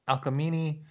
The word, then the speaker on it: Alcmene: And then we cut again back to Amphitryon is called to the shrine of Hera by Alcmene, is how we're going to pronounce her name.